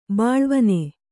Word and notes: ಬಾಲೆವೆಣ್ಣು: bāleveṇṇu

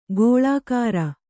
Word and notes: ಗೋಳಾಕಾರ: gōḷākāra; kannada

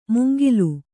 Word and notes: ಮುಂಗಿಲು: mungilu

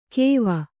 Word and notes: ಕೇವ: kēva

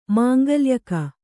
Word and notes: ಮಂಗಳ್ಯಕ: mangaḷyaka; Kannada

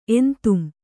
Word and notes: ಎಂತುಂ: Kannada

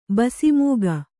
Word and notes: ಬಸಿಮೂಗ: basi mūga

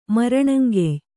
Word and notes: ಮರಣಂಗೆಯ್: kannada

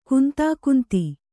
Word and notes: ಕಂದುಕುಂದು: kandu kundu; kannada

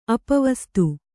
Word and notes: ಅಪವಸ್ತು: apavastu

